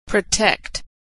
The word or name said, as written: protect